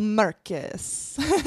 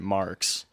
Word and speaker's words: Marcus: And thanks for actually pronouncing my last name right. – Marcus. – Yeah. So all last show, we're pronouncing it Marks. – Yeah. – Which is completely wrong.